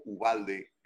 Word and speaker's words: Uvalde: Vamos a seguir platicando de todo lo que ha estado sucediendo en, en, ah, ah, en Texas, um, en Uvalde o Ubalde, como se pronuncia en español, pues es, una, es, un apellido en español.